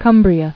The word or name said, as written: Cumbrian